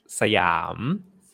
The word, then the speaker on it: Siam: Actually, we pronounce Siam.